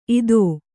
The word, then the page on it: ಇದೋ: idō